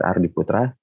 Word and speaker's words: Rd.ptra: My name is Rd.ptra.